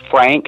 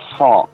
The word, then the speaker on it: Franck: Everybody pronounces it Frank. Mm. He's actually named after the composer César Franck. So it's Franck Purcell.